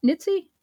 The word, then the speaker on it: Nitze: Team B were led by Harvard professor Richard Pipes and included such well known hawks as Paul Nitze, Am I saying that right? Nitzi? – I think that's right.